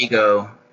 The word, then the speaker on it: Figo: The only noticeable difference was the absence of his trusted partner, Figo. – Oh, it's Figo. – Okay, Figo then. – There's a soccer player named Figo, and it's spelled like that, so that's fine.